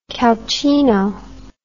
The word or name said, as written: calcino